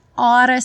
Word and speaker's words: oughta: E naquela segunda parte na repetição, we oughta stay together. Ought to, que é aquele deveríamos, devemos ficar juntos, o sinônimo do should, ele pronuncia ora, ora. We oughta stay together.